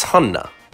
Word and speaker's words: Hanna: Like, my legal name is Hanna.